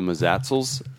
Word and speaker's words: Mazatzal: On the AZT, it's the Mazatzals. And I talked to five different locals that gave me five different pronunciations of that mountain range. I liked Mazatzal, so that's what I'm going with.